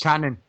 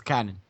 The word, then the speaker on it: Kanan: Canon, it's Canon.